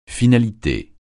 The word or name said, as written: finalité